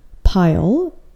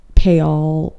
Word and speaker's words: Payal: Yes, the way you hear it is totally fine, but the way you spell it is not phonetic to the way you say it. So it's spelled P-A-Y-A-L, and I know many of you guys already know how to do this and how to read this name, but most people pronounce it Payal, Payal, PayPal.